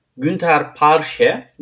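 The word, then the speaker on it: Günter Parche: O kadar yakın ee, ve bu son change olma ihtimalinin olduğu değişimde e, 39 yaşındaki fanatik bir e, Steffi Graf hayranı Günter Parşe diye okunuyor ismi.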